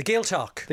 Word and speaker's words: Gaeltacht: The Gaeltacht.